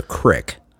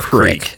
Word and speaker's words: creek: And then Southern American, it's a crick. – Yeah, that's just a mispronunciation of creek. Yeah, because we used to say crick, but that's just... – Go down to the creek. – Crick. It's just a wrong way of saying creek.